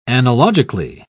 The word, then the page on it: analogically: British English